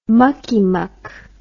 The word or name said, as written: muckymuck